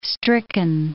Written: stricken

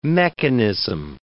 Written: mechanism

